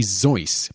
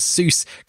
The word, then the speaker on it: Seuss: The proper pronunciation of Seuss is actually Zeus, being a Bavarian name. However, due to the fact that most pronounced it incorrectly as Seuss, Geisel later gave in and stopped correcting people,